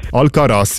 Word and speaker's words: Alcaraz: Alvarez. – Alcaraz, ja. Ja, komm, da dürfen wir beide Augen zudrücken. – Ah, nein, ich habe falsch ausgesprochen, ja.